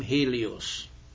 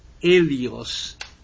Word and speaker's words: Helios: I also mentioned again, you must forgive me for my being hung up on words here, that Helios, because the H is not pronounced, you know, is very similar to Elias, the Greek word for Elijah.